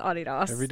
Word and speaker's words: Adidas: Because we say Adidas. – That's how they say it in Romania. And I always thought it was just because of their accent. – They say Adidas? – Mm-hmm. – Yeah. Because that's the right way to say it. – Yeah. – Yeah. Anyway, everybody just, you learn something new every day, guys. It's Adidas.